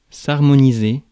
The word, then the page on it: harmoniser: France; [aʁ.mɔ.ni.ze]